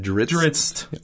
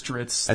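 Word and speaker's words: Drizzt: Dritz. – Wow. – I think it's Dritz.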